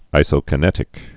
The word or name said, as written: isokinetic exercise